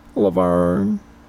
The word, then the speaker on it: Laverne: Laverne. Baragree. – Can you spell that, please? – L-A-V-E-R-N-E B-A-R-R I-G-R-Y.